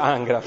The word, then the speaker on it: Ingres: Il famoso pittore che si chiama Ingres, si scrive Ingres, ma si dice Ingres in francese,